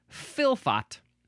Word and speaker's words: fylfot: Now we have filfot. It is spelled F-Y-L-F-O-T.